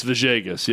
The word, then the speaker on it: Villegas: It's the same Hispanic name where the two L's are pronounced Vajay instead Vajay. Of it looks like Villegas. – Yes. – But it's Vajegas, yeah, I believe.